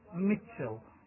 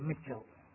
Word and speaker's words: Mitchell: That's David, D-A-V-I-T, David. – And your surname? – Mitchell. – How do you spell that? – M-I-T-H. – M-I-T-H. – T-C-H. – Yeah, T-C-H. – E. – Yeah. – L-L, Mitchell.